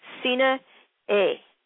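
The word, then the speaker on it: Sinne Eeg: She's a friend on Facebook, and she communicated with me right away, bless her heart, Sina A. It is spelled S-I-N-N-E and then E-E-G. The G is silent at the end. She wanted to make that very clear. That is a song called Remembering You, and you can hear the thunderstorms in the background, maybe.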